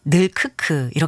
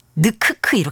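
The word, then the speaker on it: 늙크크: 허 드래곤님이 발음상 늘크크 이렇게 하셔야 돼요. 애들이 늑크크 이렇게 안 해요.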